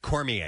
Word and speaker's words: Cormier: All right, Cormier is how you say it.